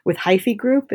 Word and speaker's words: Hyphy Group: I think you talk a lot about this geographical lens that you're taking with Hyphy Group.